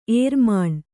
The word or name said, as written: ಏರುಮಣ್ಣು